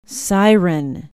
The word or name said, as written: siren